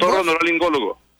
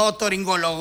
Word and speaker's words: otorrinolaringólogo: Otorrinolaringólogo. – Otorrinolaringólogo, ¿no es? – Otorrinolaringólogo, así es. – No, es otorrinolaringólogo, no otorrinolólogo, como decís vos.